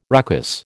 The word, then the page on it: ruckus: American English